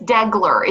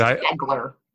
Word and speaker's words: Degler: Yeah, it's just Degler. It's just the way it's spelled, but sometimes okay. People pronounce it all different ways. But well, good. – Just Degler.